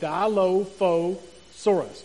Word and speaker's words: Dilophosaurus: And you pronounce it Dilophosaurus.